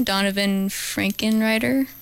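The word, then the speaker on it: Donavon Frankenreiter: Donovan Frankenreiter? – Yeah.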